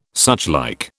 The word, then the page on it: suchlike: British English